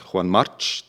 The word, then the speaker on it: Juan March: Juan Marc, Juan March.